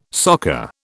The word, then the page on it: soccer: British English